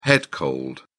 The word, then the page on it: head cold: British English